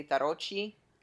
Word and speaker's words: tarocchi: In Italy, they say tarocchi.